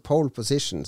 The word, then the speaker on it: pole position: Ja, han med poleposisjon, som jeg alltid kalte det når jeg var barn, og ikke visste hvordan jeg skulle uttale ting, så pole position, som det egentlig heter, men poleposisjon, det var jo et racing-spill.